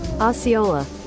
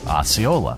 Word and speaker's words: Osceola: How do you pronounce that? I should be able to pronounce it, but I'm just blanking. Sorry, gang. Okay, here we go. – Osceola. – Osceola. All right, thank you.